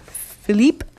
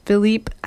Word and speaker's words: Philippe: Queer Fast Track airs at 5:30 with Philippe. And I have to apologize. I think I don't know how to pronounce his name. It's Philippe. I've been calling him Philip for like a year and a half.